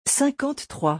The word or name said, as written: 53